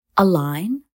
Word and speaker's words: align: لا يتم نطق الحرف G في المجموعة GN في نهاية الكلمة مثل align وsign